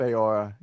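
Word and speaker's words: Faora: So what I think is that this is that the female general that's usually with Zod, I can't pronounce her, is it Feora? – It's Feora, yeah.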